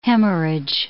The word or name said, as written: hemorrhage